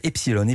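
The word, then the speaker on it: Epsiloon: Epsilon avec deux O, mais ça se prononce quand même Epsilon.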